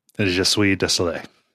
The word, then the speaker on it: Je suis désolé: Jesu de Seoul, Japan, but Quebec has taken the classic NES crown. I'm sure I mispronounced that. – Je suis désolé.